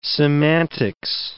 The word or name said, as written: semantics